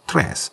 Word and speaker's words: Cres: It's spelled C-R-E-S, but pronounced Tres.